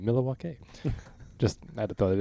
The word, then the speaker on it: Milwaukee: He's like, he goes, actually, it's pronounced Milwaukee, which means something of the land in Algonquin.